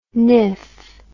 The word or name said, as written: niff